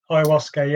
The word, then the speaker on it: ayahuasca: And then you started to tell me about, I think I keep saying it right, an ayahuasca ceremony. – Yeah, ayahuasca, yeah.